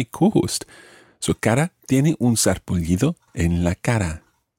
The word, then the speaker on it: sarpullido: Se siente un poco mareada y, and there's something else wrong with Cara, tiene, she has, un sarpullido. That's one of those words that it's very difficult to guess what it means. Un sarpullido, sarpullido. It's got a double L sound at the end there.